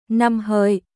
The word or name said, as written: Năm Hợi